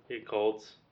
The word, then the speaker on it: Yakult: Yakult.